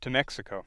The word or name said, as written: Mexico